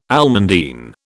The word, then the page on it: almandine: British English